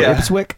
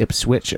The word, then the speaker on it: Ipswich: or how to pronounce Ipswich or Ipswick. So I was just, looking it up, and it's actually Ipswich, according to Wikipedia.